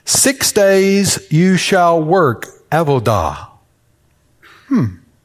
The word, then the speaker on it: avodah: So I've spent all week living in Hebrew words, and I've got a Hebrew word for you avodah.